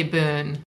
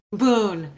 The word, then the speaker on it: Bohn: It's actually Boone. Boone. Okay. Now, and spell that for everyone. That's B O H N. It it makes no sense to me.